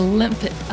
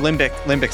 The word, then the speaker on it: limbic: The hippocampus is part of the limbic. What is that? How do you say that? – Limbic system.